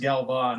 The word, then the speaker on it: Galvan: I think it's technically pronounced Galvan because it's Spanish, but I've used Galvin my entire life, so I don't really care.